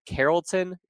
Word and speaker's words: Carrollton: He's the center out of, Don, correct me if I mispronounce it, Carrollton, Georgia. – Yes.